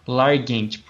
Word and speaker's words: Largent: O outro foi o Steve Langa em, 1988. Largent, corrigindo aqui a, a pronúncia, em 1988 é isso, cara.